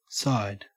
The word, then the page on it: side: Australian English